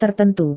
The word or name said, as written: tertentu